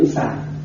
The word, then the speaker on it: xoài: Và chữ xoài á, theo miền Tây người ta phát âm là chữ xài. Mua thì mua xoài, mà ăn thì ăn xài.